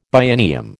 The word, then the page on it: biennium: American English